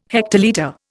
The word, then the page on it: hectolitre: Indian